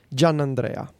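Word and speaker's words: Giannandrea: The Italian version would be Gianandrea. – That's John how Andrea.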